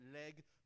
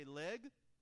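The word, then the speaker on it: leg godt: And so he created this thing, and he called it Legot. We're going to participate. Everyone say Legot. You got to say it like you got a loogie in your throat. Legot. It means play well.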